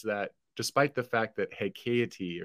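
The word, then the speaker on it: haecceity: Nevertheless, hexaity is an English word, yeah. – and the most common way to pronounce that word is hexaity.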